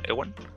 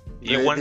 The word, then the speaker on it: Ewan: Creo que, creo que Evan McGregor o Ewan, ¿cómo se pronuncia? Ewan. – Ewan, dice que todo el mundo lo pronuncia diferente, así que.